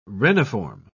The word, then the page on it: reniform: British English